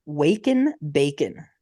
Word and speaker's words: Wake N' Bacon: And this place is called Waken Bacon. W A K E N, bacon, kind of like wake and bake, but it's spelled bacon.